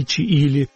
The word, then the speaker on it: Чиили: У меня это ассоциировалось с государством Чили, хотя пишется через два «и» Чиили.